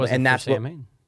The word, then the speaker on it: Samhain: It's actually Samhain. That's how you pronounce it. You don't pronounce it Samhain.